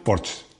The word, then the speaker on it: Porsche: ¿cómo se pronuncia Porsche? Bueno, pues se pronuncia Porsche. Se pronuncia, además me lo había dicho Rodrigo, no le había hecho ni caso, claro, pero a Porsche sí le hago caso. Es que se pronuncia en alemán, Porsche, o sea que es Porsche.